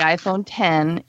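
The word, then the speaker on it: iPhone X: They announced the iPhone 10. It is an iPhone with a letter X, and we're going to pronounce it 10, because, you know, you've got to be able to distinguish between the dweebs and well, the muggles, I guess.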